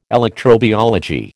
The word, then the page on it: electrobiology: American English